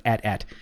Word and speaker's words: AT-AT: 10 years ago, I think it was, that it's pronounced AT-AT. We don't care. Like, whatever. But it is officially, yes, it is AT-AT.